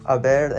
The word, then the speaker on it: haber: Keep in mind that the H, or the H, and these conjugations is silent. We do not pronounce the H. Haber in the present preterite is yo hube,